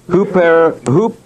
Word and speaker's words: huperetes: The word minister is huperetes. H-U-P-E-R-E-T-E-S. And the first E and the last E, you put a long mark over it. H-U-P, long E, R-E-T, long E, S.